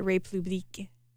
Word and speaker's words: République: The cookbook is called Baking at République. I don't know why I really struggle. It's Republic, but it's the French pronunciation.